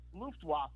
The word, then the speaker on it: Luftwaffe: Is it the German version, Luftwaffe, or is it an Americanized version? – Luftwaffe. – I thought so. – Luftwaffe, that's how we pronounce it.